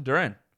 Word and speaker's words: Duren: And his name is Jalen Duren. I know it's spelt slightly differently, one letter different, but that is what that is.